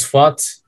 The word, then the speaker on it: Fuat: I am Fuat. My Turkish name is Fuat, but I use Sean because it's easier to pronounce.